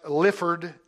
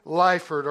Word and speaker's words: Lyford: His last name may be pronounced Lyford. It's spelled L-Y-F-O-R-D.